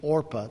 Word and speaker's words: Orpah: But as a little aside, Oprah Winfrey's name is actually Orpah. This is who she was named after. That's what was put on her birth certificate, but everybody mispronounced it, so she became Oprah.